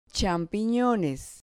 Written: champinones

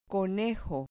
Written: conejo

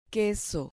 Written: queso

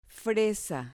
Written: fresa

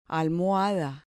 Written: almohada

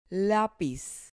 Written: lapiz

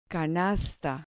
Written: canasta

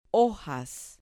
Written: hojas

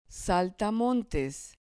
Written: saltamontes

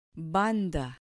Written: banda

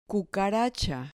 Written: cucaracha

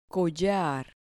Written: collar

